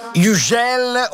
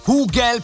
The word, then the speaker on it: Hugel: Hugel, oppure potete pronunciarlo anche Hugel, perché lui è nato a Marsiglia, quindi è francese Hugel, però fa delle produzioni in Germania, quindi in alcune interviste si fa chiamare Hugel.